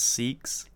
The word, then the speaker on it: Seax: I'm going to assume that it's pronounced Seeks. – Yeah. – S-E-A-X.